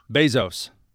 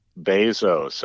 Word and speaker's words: Bezos: The Amazon founder's last name is Jeff Bezos. – Bezos.